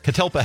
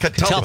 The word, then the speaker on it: Catalpa: I cannot pronounce the name of your last place again. Calapa. – Catalpa Health. – Catalpa. It's a tree.